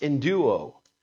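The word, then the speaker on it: enduo (ἐνδύω): It's from a Greek word called that says a Greek word that's pronounced enduo.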